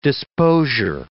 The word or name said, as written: disposure